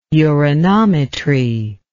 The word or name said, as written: uranometry